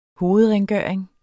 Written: hovedrengøring

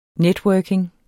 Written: networking

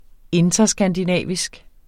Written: interskandinavisk

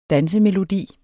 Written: dansemelodi

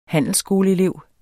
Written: handelsskoleelev

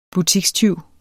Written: butikstyv

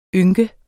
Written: ynk

